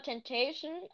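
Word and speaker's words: XXXTentacion: Aber genau, letztes Jahr habe ich so viel XXX Tentation gehört. So wird der, glaube ich, ausgesprochen, wenn man. Also, keine Ahnung, es gibt verschiedene aus. Ja? – Ich sag immer Temptation, aber zum Beispiel Alexa oder Computer. Ähm, die, die sagen immer äh, Tentation.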